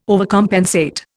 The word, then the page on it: overcompensate: Indian